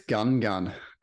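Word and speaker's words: Ngungun: Yeah, I think it's Gungun, but there's an N in there, silent N at the start, which gets everybody.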